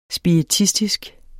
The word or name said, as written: spiritistisk